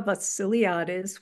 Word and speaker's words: Vassiliadis: My name is Demitra Vassiliadis, which kind of rhymes with the silly artist. Good way to remember.